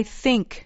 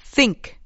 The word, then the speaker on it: think: I think. Th think. – I think. به تلفظ دقت کنید. باید زبونی بگید. علاوه بر اون بعد از صدای تلفظش ای نیست یه صدای بین ای و ا بگید من فکر میکنم